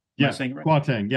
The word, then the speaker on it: Kwarteng: How does he say his name? Kwarteng? – Kwarteng. Yeah.